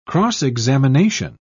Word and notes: cross-examination: British English

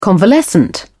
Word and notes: convalescent: British English

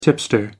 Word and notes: tipster: American English

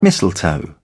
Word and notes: mistletoe: British English

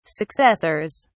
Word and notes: successors: British English

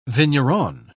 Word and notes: vigneron: British English

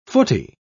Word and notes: footie: British English

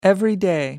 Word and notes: everyday: American English

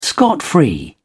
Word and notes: scot free: British English

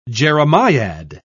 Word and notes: jeremiad: British English

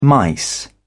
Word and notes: mice: British English

